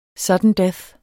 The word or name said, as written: sudden death